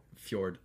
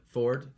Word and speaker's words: fjord: But it means down by the fjord. Ford? – Fjord. – Like the river.